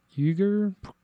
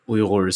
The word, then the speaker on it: Uyghur: The correct way, or the way that Uyghurs say it, is Uyghur, but not a lot of people are able to say the more guttural GH sound. So the Western pronunciation of it is Uyghur.